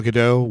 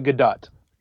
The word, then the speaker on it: Gadot: Is it Gadot or Gadot? Gal Gadot, Wonder Woman. – It's uh, Gadot. – Gadot.